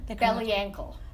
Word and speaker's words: Belyankel: Belyankel. – Belyankel. – It's phonetic, so it's pronounced exactly the way it's said.